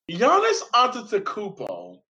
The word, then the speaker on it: Giannis Antetokounmpo: But I wanted to talk about the All-Star game because Giannis Antetokounmpo, and I said his name right, is a terrible GM, and his reasoning for not drafting James Harden backfired.